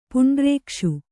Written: ಪುಂಡ್ರೇಕ್ಷು

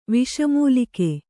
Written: ವಿಷಮೂಲಿಕೆ

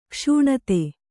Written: ಕ್ಷಣದೆ

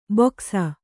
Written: ಬೊಕಸ

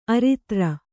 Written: ಆರಿದ್ರ

